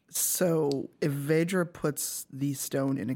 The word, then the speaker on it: Vajra: It's Vajra. – Vajra. – You know, the A, like the vowel is pronounced like a ah.